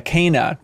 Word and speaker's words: Kena: Mine was probably, I don't know if it's pronounced Kena or Kena. – Yeah, Kena, technically. – Kena. Mm-hmm. – Like canine.